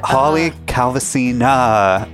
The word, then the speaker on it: Calvasina: So close. No. It's Holly Calvasina. – Holly Calvasina.